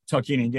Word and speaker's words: Tarkanian: Tarkanian? – Tarkanian, yeah.